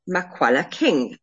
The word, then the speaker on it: Makwala King: It's Sara-Jayne Makwala King.